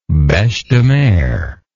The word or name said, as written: bechedemer